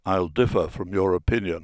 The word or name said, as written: differ